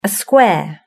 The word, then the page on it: a square: British English